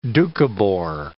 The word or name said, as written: Dukhobor